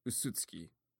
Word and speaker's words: Usutsuki: Oh, I'm mispronouncing that word. So, Usutsuki. There we go. Okay. I was saying beautiful, which was not making any sense.